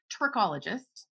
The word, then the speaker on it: trichologist: And you're a trichologist.